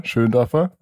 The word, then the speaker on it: Schöndorfer: Ja, mein Name ist Oliver Schöndorfer, wie du schon richtig ausgesprochen hast vorher. Perfekt. Sehr schön auch, dass mein Nachname korrekt ausgesprochen wird, weil ich bin häufig auf englischsprachigen Podcasts und das ist die größte Herausforderung, wie man sich vorstellen kann.